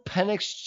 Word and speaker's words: Penix: They should draft at number 9, Michael Penix Jr., the quarterback out of Washington.